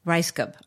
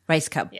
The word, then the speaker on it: Rajskub: Rashab? Mary Lynn Rajskub. Rice Cub. – Okay. So you tra- Rajskub. Rice Cub. Yeah.